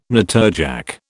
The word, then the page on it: natterjack: British English